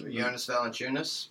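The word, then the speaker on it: Valančiūnas: Jonas Valanciunas.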